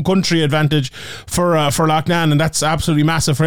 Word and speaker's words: Loughnane: Loughnane is from like my part of the world here, and they call it Loughnane in England, but I'm going to continue calling it Loughnane because that's how you pronounce it. He's he's of Irish descent. It's Loughnane. He's the one pronouncing it wrong. I'm pronouncing it right.